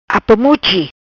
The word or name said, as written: Apamuchi